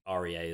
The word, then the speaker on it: Rea: Very to the point from Stephen Ray or Rhea, because I think REA is Rhea, right? That's how you pronounce yeah, that surname. It's Chris, Chris Rea. Like Chris Rea driving yeah. home for Christmas.